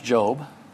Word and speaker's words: Job: How about Job? Or Job, if you're new to the Bible. Turn to Job 1. But it is pronounced Job.